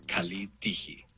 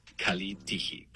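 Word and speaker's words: kalitiji (καλή τύχη): En el griego moderno se dice kalitiji. Kalitiji. Pronunciación aproximada. Kalitiji.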